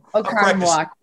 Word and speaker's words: Oconomowoc: I saw one of your brothers had a birthplace, Trenton, who's playing hockey, had a birthplace in Oconomowoc. Did I say that right? – Yeah. – Yeah. – Yep. Oconomowoc.